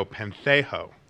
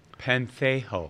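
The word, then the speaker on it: pentheō: But then also when we look at the word mourn or remorse, it actually comes from the Greek word, meaning it's pronounced, ready for this? Here we go, pentheho. – Pentheho. – Pentheho.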